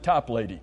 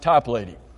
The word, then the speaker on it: Toplady: And I have looked all over trying to find out how to pronounce his name, and everybody says to pronounce it Toplady. So if you want to argue with me, just argue with Google and the internet. They're the ones who told me to make sure you pronounce it Toplady.